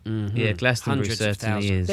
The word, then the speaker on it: Glastonbury: But the festivals, I mean, as somebody said here last week, they said, if you haven't been to Glastonbury yet, or Glastonbury, I got to say it properly, you know, you haven't lived.